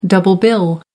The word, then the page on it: double bill: American English